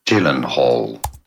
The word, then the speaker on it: Gyllenhaal: In English, in the US, pronounced as Gyllenhaal.